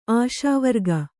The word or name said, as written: ಆಶಾವರ್ಗ